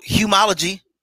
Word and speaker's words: humology: So talk about more about humanology. I love that. I love that whole phrase. Did I say it right? – Well, yeah, you could say it that way. It's humology, you know.